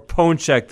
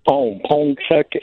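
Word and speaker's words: pączek: The singular would be Punchek. – More Punchek than Punchek. – And there's it's not really an N. It kind of just goes nasal. French has a similar pronunciation. Ponchek and Ponchki.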